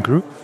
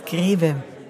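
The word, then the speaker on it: Grewe: In German, his name is Greve. Benjamin used to work at Stanford University, and that's why he calls himself Gru. People in the US never got the German pronunciation of his name right.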